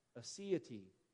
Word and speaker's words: aseity: The theological term is aseity. It is spelled A-S-E-I-T-Y, sometimes pronounced aseity.